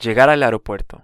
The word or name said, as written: llegar al aeropuerto